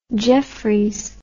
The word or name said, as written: Jefferies